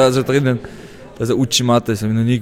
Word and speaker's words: Uchimata: Dort stand auch das Uchimata, dein Lieblingstechnik ist, ich habe es falsch ausgesprochen, oder? – Lala, das war schon richtig so.